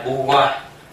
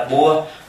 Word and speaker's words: Bua: Cái tên của ngài là Bu Hoa, nhưng mà sự thật tên tiếng Thái đọc là Bua.